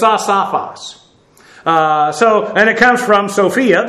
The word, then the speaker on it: asophos: A-S-O-P-H-O-S. Asophos, and you'll note, I understand the anglicized version of Greek. Every time people see an O, it's always a long O. Asophos. Well, that's not how it's pronounced.